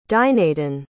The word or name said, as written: dinadan